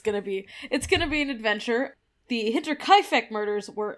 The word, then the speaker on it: Hinterkaifeck: That's right, we're doing the Hinterkaifeck, and I will be saying it like that every single time. – That's how it's pronounced.